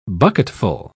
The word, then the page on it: bucketful: British English